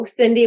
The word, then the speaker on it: Sindy: And my name is spelled with an S. So I see on the sign, go Sindy with an S, go.